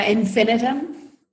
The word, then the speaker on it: infinitum: Yeah, infinitum. Infinitum. Sometimes it helps infinitum. People say it that way.